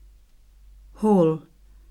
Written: hall

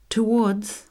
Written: towards

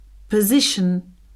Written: position

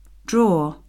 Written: drawer

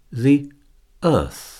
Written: the Earth

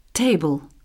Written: table

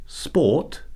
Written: sport

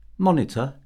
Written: monitor

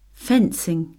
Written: fencing

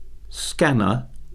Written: scanner

